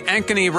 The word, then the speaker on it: Ankeny: A-N-K-E-N-Y.